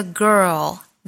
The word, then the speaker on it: girl: About that girl, the one I let get away.美音的发音呢也大体是一致的，我们来看有哪些差别。There's a girl, there's, girl, girl, 这个单词美音的发音方法是 er, 后面再加一个 l 的发音。卷舌的 er 音 er, 前面再和辅音组合发 girl,